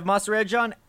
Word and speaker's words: Mahserejian: Yeah, so my name is Kev Mahserejian, at Rotosurgeon on Twitter. It's spelled how it sounds.